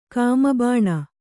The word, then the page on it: ಕಾಮಬಾಣ: kannada